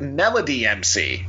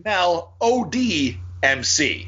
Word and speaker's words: Melody MC: I was pronouncing it Melody MC, but Maestro called himself his first name Melody MC. So like Mel O.D. MC.